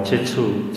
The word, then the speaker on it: Jeju: se escribe en, carácter latino como Jeju, J, pero se pronuncia como Chechu, Chechu Island, Chechu Chao.